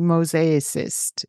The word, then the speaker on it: mosaicist: Okay, so what does it look like to become a mosaicist? Am I saying it correctly? – Yes.